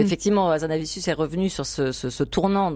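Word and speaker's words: Hazanavicius: C'était en 2017 de Michel Azanavicius.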